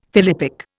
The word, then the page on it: philippic: Indian